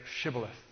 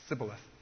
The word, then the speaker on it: Shibboleth: Say Shibboleth. They could not say Sh. The sheen of the Hebrew language. They pronounced it S, the seam. Say Shibboleth.